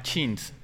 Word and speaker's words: Shinx: hay uno que es un lince que se llama Chins. Se escribe S-H-I-N-X.